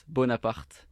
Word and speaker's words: Bonaparte: Okay, so it's Bonaparte. – Okay. – With a strong R.